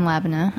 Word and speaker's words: Labneh: And you sell three types of yogurts, Persian, Greek, and Labneh. – Mm-hmm. – Am I pronouncing that right? – Yep.